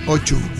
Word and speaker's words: O2: ¿Pero no se dice Chu en inglés? – Sí, O2. O2.